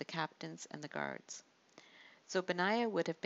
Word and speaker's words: Benaiah: His name is pronounced Benaiah, and it means built by Jehovah.